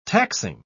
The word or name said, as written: taxing